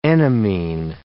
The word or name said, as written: enamine